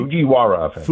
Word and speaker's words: Fujiwara: It's the Fujirawa. – Fujiwara. I got that right? – Fujiwara effect.